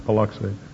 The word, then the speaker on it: Paluxy: Is that the way you pronounce that river, Paluxy? A good Texan, is it? Someone is nodding like this.